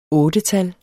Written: ottetal

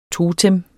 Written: totem

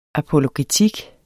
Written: apologetik

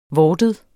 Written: vortet

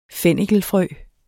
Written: fennikelfrø

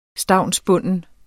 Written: stavnsbunden

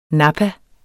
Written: nappa

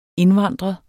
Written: indvandrer

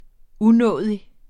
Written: unådig

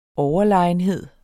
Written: overlegenhed

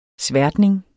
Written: sværtning

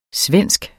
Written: svensk

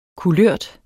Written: kulørt

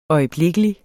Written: øjeblikkelig

